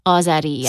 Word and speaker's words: Azeri: Azeri, yeah.